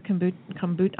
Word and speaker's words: kombucha: I always say that kombucha. – Wrong. Kombucha. – Yes. – Is that true with kombucha as well? – It is. And by the way, most people mispronounce kombucha.